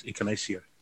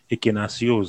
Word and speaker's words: Ekenasio: And even our very own Pulse shooter, Emilioran Ekenasio, gets Ekenasio. Ekenasio. Ekenasio is a pronunciation.